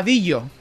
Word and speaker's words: Dillo: O sea, como si fuera Dillo, D-I-L-L-O. – Sí, sí. En castellano se leería Dillo, vamos. – Dillo, pero es el Dilo. – Sí, creo que yo, que se pronuncia Dillo, vamos, como una L geminada, creo que son de francés, ¿no? – Sí, pues no, madre mía.